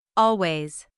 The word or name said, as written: always